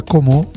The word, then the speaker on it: Como: Con la O stretta, perché lì, sai, parlano così, sono di Como. – Senza l'accento finale, semplicemente sarebbe Como.